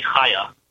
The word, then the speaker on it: Chaya: It's Hebrew. It's Hebrew with a chet, but in English we write with a C-H usually, Chaya.